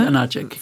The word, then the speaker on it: Janáček: Janáček.